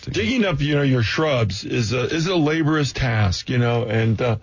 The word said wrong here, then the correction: laborious: Laborious. – Laborious. – You got the accent on the wrong syllable.